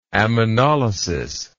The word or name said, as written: ammonolysis